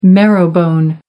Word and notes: marrowbone: American English

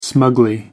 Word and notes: smugly: American English

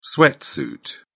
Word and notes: sweatsuit: British English

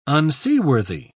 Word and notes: unseaworthy: British English

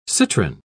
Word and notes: citrin: British English

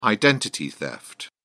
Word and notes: identity theft: British English

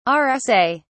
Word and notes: rsa: British English

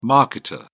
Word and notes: marketer: British English

